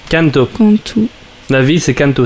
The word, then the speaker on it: Kanto: Personnellement, en français, on prononce Kanto. Ah, voilà. Euh, après, je sais absolument pas comment ça se prononce en japonais, donc je ne pas à ce niveau-là. Kanto. je parle un peu fort dans le micro. – Alors, pour la parenthèse, en japonais, ça c'est du Kanto.